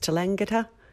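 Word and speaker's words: Tallangatta: There were reports of List being seen in Kensington, Footscray, Mornington, Maryborough and I think it's pronounced Talangatta.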